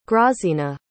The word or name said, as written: grazina